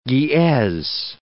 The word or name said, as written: Geez